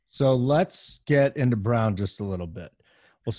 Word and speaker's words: Braun: Christian Brown is spelled Braun, but it's Brown.